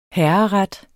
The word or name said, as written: HERRERETTERNES